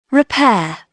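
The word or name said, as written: repair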